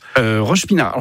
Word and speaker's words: Roche-Pinard: À Roche-Pinard. Alors, c'est bien comme ça qu'on prononce. – Roche-Pinard.